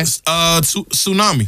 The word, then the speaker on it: tsunami: This has a silent letter in it. – Okay. – Tsunami. – Tsunami. – Tsunami. – Tsunami. – Sure does. – Now, some people might say tsunami. – Tsunami. – Tsunami. Somebody might say that. They'd be wrong.